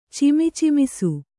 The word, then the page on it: ಚಿಮಿಚಿಮಿಸು: Kannada